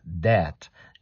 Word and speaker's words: debt: Debt. You keep the B silent. Eat it up. Don't be in debt with debt, right? You don't pronounce the B.